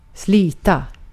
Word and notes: toil: /ˈtɔɪl/

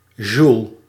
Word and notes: joule: [ʒul]; /ʒul/

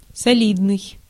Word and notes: солидный: /sɐˈlʲidnɨj/

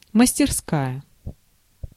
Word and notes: мастерская: /məsʲtʲɪrsˈkajə/

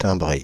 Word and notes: timbré: [tɛ̃.bʁe]; Paris

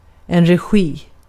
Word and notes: regi: /rɛˈɧiː/

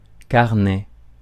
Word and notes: carnet: [kaʁ.nɛ]

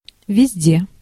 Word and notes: partout: /paʁ.tu/

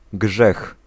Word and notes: péché: [pe.ʃe]; France

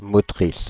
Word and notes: motrice: Paris; [mɔ.tʁis]; /mɔ.tʁis/

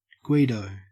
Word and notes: Guido: Australian English

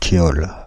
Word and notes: thiol: France (Paris); IPA: /tjɔl/; French

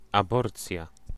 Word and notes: avortement: France; [a.voʁ.tə.mɑ̃]

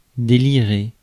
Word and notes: délirer: /de.li.ʁe/

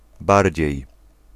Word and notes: more: /mɔɹ/; [mɔɹ]; [mɔː]; /mɔː(ɹ)/; /moʊɹ/

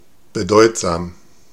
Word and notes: considérable: France; [kɔ̃.si.de.ʁabl]

